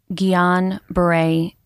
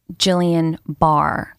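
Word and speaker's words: Guillain-Barré: So, GBS is pronounced Guillain Barre syndrome. A lot of people think it's pronounced Jillian Barr, uh, which makes sense.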